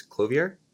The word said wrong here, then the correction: Clovyr: is the pronunciation. – Just Clover.